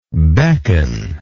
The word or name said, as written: beckoningly